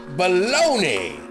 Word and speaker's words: Bologna: Bologna, that's your hometown, ain't it, Guido? Well, hey, in this country, uh, in the U.S. of America, A, that is pronounced baloney.